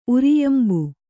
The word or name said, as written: ಉರಿಯಂಬು